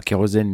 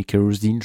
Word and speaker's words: Kerosene: sont excellents Shock Me Kerosene enfin même si ça se prononce pas Kerosene mais Kerosene je suppose